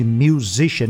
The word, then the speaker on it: musician: Então, olha aí, ó, a pronúncia de musician. Me deve 1.000, Zishan. Então, pronúncia de musician é com o número 1.000 e mais zishan.